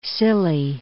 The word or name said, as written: Scilly, Isles of